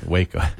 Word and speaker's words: Waco: Is that Waco or Waco? Waco. – Yeah. – Okay. – Excuse me. It doesn't rhyme with taco. It's Waco.